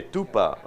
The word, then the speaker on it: tupa: How do you say tuba? P-A, tupa.